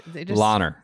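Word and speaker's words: Langer: Are getting mad at my pronunciation. – Lahner. Say you don't pronounce the G.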